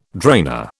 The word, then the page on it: drainer: British English